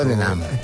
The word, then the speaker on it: Tottenham: Tú sabes que Tottenham, en inglés, en británico, se pronuncia así la EH al medio de espectro. Tottenham.